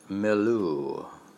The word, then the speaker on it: milieu: Involved in the New England Calvinist milieu.